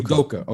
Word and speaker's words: Udoka: I'm a big, I hope I'm pronouncing his name, Ime Udoka, right? Udoka. – Udoka.